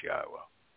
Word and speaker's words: Yahweh: and that it is pronounced Yahweh,